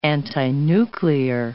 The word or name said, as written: antinuclear